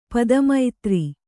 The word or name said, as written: ಪದಮೈತ್ರಿ